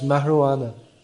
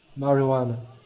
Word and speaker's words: marijuana: It's pronounced marijuana. The J in Spanish is pronounced as an H, marijuana.